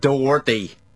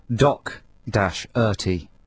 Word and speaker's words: Doherty: Because it says here on our notes with our playlist, pronounced Doc-Erty. – Oh, are you supposed to... a hard C.